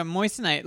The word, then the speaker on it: moissanite: And go to moissanite. Let me read a little bit about moissanite. Am I even pronouncing it right? – Moissanite? – Yeah. – No, that is correct.